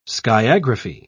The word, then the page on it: skiagraphy: British English